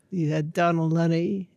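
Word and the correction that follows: Dónal Lunny: and... If I pronounce that correctly. – Yeah, Donal Lunny, Michal O'Donnell, and Triana, Michal's sister.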